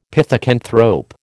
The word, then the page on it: pithecanthrope: American English